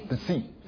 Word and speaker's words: Psi: Upsilon T 的下面啊，要 Phi Chi Psi Psi 是 S 的发音，但是它是用 P 的前嘴唇来带出去。